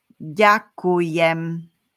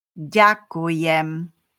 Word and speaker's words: Ďakujem: Ďakujem. One more time. Ďakujem.